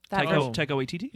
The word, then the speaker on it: Taika Waititi: Taika Waititi? – Yeah.